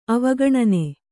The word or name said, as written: ಅವಗಣನೆ